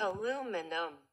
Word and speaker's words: aluminum: Aluminum. – So what is it then? – She sounded a little like she ain't even sure. – One had the British accent and the other had this American pronunciation.